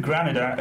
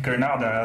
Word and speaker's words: Granada: Let's start with Real Madrid Granada again, Granada as it pronounced rather.